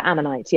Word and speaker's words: Ammonite: Yeah, Ammonite, yeah.